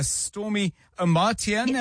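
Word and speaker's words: Stormie Omartian: It's called The Power of a Praying Woman by Stormy Amartian.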